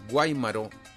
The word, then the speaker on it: Guaymaro: Guaymaro es municiones, con acento en la A primero, porque está Guaymaro, que es un apellido, pero Guaymaro es municiones.